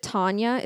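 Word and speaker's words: Tonia: So she knocked on the door and I think you pronounce it Tanya. It's T-O-N-I-A.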